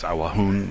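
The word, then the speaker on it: Awajún: So they were known historically as Aguaruna, but their own self-denomination was Awahun, which is closely related to it, and starting in the late 1980s, they started using that in preference to Aguaruna.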